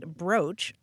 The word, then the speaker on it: brooch: Um, it turns out that uh, most dictionaries will tell you it's pronounced brooch, although some do countenance the pronunciation brooch.